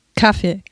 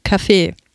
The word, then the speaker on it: Kaffee: Auf welcher Silbe betonen die Deutschen das Wort Kaffee? Meine Bekannten betonen es auf der ersten Silbe. Kaffee. Diese Betonung bevorzugt auch der Duden. Doch nahezu alle Schauspieler und Sprecher, auch der von mir hochverehrte, leider schon verstorbene Gerd Westphal, der uns hinreißende Hörbücher hinterlassen hat, betonen es auf der zweiten Silbe.